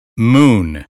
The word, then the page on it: moon: American English